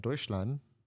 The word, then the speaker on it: Deutschland: Now we got to take a trip down to Deutschland.